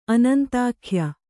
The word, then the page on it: ಅನಂತಕಾಯ: anantakāya